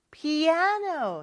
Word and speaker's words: piano: I play the piano, piano. 이렇게 두 번째 음절에 강세가 있는 거 잘 기억해 주세요.